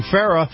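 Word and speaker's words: Farah: And this portion of the program is brought to you by our friends at Farrah & Farrah. Now, Farrah & Farrah is spelled F-A-R-A-H, F-A-R-A-H.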